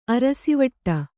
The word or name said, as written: ಅರಸಿವಟ್ಟ